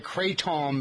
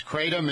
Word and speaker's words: kratom: I've called it kratom before, but it's spelled K-R-A-T-O-M. I guess it's called kratom.